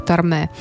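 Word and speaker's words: Tormé: Отвечая на вопросы журналистов о своем происхождении, он часто любил повторять, хоть моя фамилия и произносится на французский манер с ударением на последний слог «Торме», но фактически она имеет русские корни «Торма», потому что мои предки, евреи, когда-то давным-давно приехали в Америку из России.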